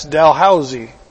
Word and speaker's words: Dalhousie: And its name is spelled D-A-L-H-O-U-S-I-E. In most places, that's Dalhousie, but in Brantford, it's Dalhousie. Now, if I met someone from Newfoundland, I would know instantly where they are from the moment they said something.